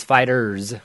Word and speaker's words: FighterZ: It's not called Dragon Ball FighterZ. The Z is part of Fighter, and it's Fighters with the Z at the end.